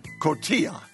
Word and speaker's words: cotija: But Zorba, before our next call, garlic roasted asparagus with cotija. – Yeah, good. You pronounced it well.